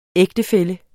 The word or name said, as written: ægtefælle